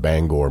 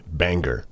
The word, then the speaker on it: Bangor: We hate it when people call our hometown Bangor, Maine, Banger.